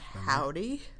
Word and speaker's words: Hovvdy: Called Howdy. That's spelled H-O-V-V-D-Y because, of course, it is.